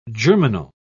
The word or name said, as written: germinal